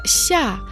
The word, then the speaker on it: 下: Слово «ся» произносится в четвертом тоне.